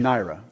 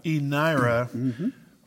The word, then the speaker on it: eNaira: The eNaira. – eNaira,